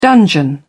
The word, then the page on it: dungeon: British English